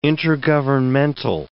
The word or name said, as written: intergovernmental